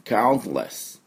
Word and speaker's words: countless: まあ、アメリカ人が発音すると T の音が弱まりますので、 count、こうやってって詰まるだけで、 less。 countless。イギリスだと countless。で、しっかり T、音をね、出したがりますので、多少ね、音が違ってくると思いますが、私はどうしてもアメリカの音になりますので、 countless。